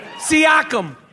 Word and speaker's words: Siakam: Siakam.